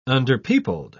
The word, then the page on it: underpeopled: British English